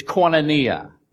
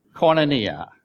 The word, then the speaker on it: koinonia: But the one that I remember is koinonia. Koinonia. That is the Greek word for communication. It is spelled K-O-I-N-O-N-I-A, if you're taking notes.